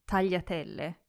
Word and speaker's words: tagliatelle: And earlier I mentioned a different type of pasta, tagliatelle.